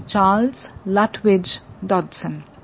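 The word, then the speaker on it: Charles Lutwidge Dodgson: If my pronunciation of his name was not clear, it's Charles Lutwidge Dodson.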